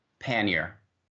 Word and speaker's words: pannier: Pannier.